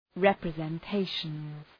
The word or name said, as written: representations